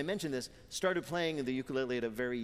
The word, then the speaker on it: ukulele: Peter's first question to Taimane: how the hell do you pronounce ukulele? – Ukulele. – Ukulele. – Uku. – Ukulele. Lele. – You got it.